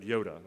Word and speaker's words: iota: Some of you are just realizing that's how you pronounce that word, Yoda. It's not like Star Wars Yoda. It's Yoda like in Hebrew.